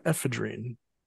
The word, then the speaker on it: ephedrine: Atropine, scopolamine, and ephedrine.